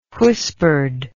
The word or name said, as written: whispered